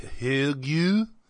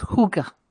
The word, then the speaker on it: hygge: I wish, but it's not even spelled like that. It's spelled H-Y-G-G-E. – Higgy? – But it's pronounced hookah.